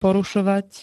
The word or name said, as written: porušovať